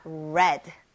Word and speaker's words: read: 그래서 이 스펠링은 똑같은데 이게 굉장히 재미있는 게 발음만 바뀝니다. read가 아니라 red. 네, 맞아요. 색깔 빨간색처럼 red라고 읽어야 돼요.